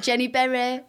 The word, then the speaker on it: Jenny Berry: They were like, Jenny Berry, it rhymes.